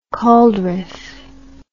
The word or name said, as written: cauldrife